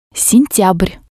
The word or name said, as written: sentyabr